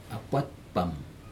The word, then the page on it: apat pam: Aneityum